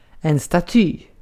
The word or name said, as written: staty